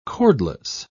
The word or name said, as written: cordless